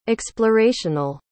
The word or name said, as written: explorational